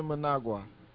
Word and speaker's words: Mnangagwa: And you spell it MNANGAGWA. So we're gonna call him E. Emerson. – No, it's Emerson Mnangagwa. – That's Mnangagwa. All right, there it is.